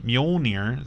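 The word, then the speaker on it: Mjölnir: But Thor's hammer, Mjolnir, is that how you pronounce it? – Yeah.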